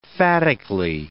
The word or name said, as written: phatically